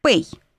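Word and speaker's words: Пэй (פ): Пэй. П, если в начале слова. Иначе Ф.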